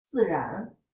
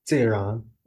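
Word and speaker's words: Ziran (自然): Ziran. – Ziran.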